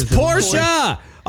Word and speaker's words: Porsche: Porsche? – It's Porsche. Oh, my gosh. You can't even say it right, let alone spell it.